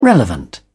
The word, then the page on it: relevant: British English